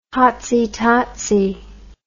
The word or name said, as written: hotsie totsie